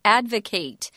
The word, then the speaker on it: advocate: If you use it in the verb form, it's pronounced differently. It's advocate, which means you're going to propose or support an idea you believe in.